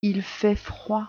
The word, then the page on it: il fait froid: French